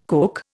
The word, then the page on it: Coke: Indian